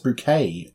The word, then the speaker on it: Bucket: who insists that her name is actually pronounced Bouquet due to middle class pretensions.